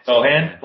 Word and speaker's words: Sochan: I saw a video of Victor Wembanyama, like, trying to throw a full quarter, and Sochan, is that how you say his name? – Sochan.